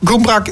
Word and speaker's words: Gumrak: Gumrak, dat is een plek in Rusland, geloof ik, of Oekraïne? – Ja, Gumrak.